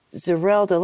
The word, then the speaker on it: Zerelda: Zerelda, let's talk about his mom for a moment.